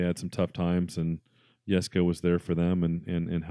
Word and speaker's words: Jesko: So Christian's dad, Jesko, that's how you pronounce it, with a Y rather than a J.